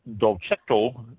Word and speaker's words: Dolcetto: It's spelled D-O-L-C-E-T-T-O, Dolcetto.